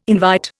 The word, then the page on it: invite: Indian